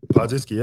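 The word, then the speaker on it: Podziemski: I think I'm pronouncing that correctly. – It's a good try. – Yeah. What is that, right? – Podzimski. Yeah, that's right.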